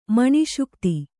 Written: ಮನಸೋಕ್ತ

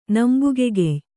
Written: ನಂಬುಗೆಗೆಯ್

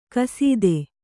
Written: ಕಸೀದೆ

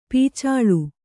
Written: ಪೊಚ್ಚಳ

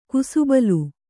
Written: ಕುಸುಬಲು

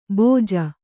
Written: ಭೋಜ

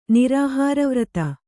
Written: ನಿರಾಹಾರವ್ರತ

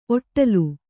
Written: ಒಡಲು

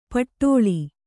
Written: ಪಟ್ಟಳಿ

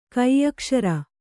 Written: ಕೆಯ್ಕುಸುರಿ

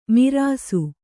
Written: ಮಾರ್ಷ

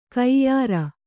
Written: ಕೈಯಾರು

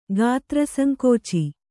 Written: ಗಾತ್ರಸಂಕೋಚಿ

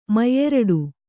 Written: ಮುಯ್ಯೀರಡಿ

